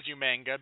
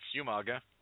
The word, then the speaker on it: Umaga: William Regal, for months, pronounced it as Umanga because with the spelling in the uh, tip in traditional Samoan, that's the correct pronunciation, but no, it's Umaga.